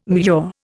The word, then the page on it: mure: Indian